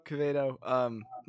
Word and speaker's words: Quevedo: Quevedo. My family pronounces it different ways, so it's really it's up to interpretation.